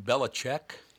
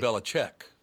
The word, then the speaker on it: Belichick: Isn't his name Belichick? – It looks like Belichick. – What is Belichick? – Yep. – But he pronounces it Belichick.